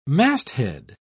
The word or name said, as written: masthead